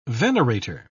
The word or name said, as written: venerator